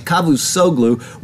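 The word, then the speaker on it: Çavuşoğlu: Turkish Foreign Minister Cavusoglu, that's how you say it, Cavusoglu, warned that Ankara would respond if the U.S.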